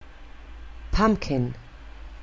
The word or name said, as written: pumpkin